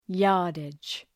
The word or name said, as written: yardage